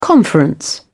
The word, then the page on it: conference: British English